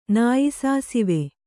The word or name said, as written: ನಾಯಿಸಾಸಿವೆ